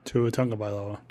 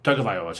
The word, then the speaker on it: Tagovailoa: It's not like he's Tua Tagovailoa, or whatever, however you pronounce his name. Sorry, dude. – Tua Tagovailoa. – Tagovailoa, sorry. I knew I'd butcher it.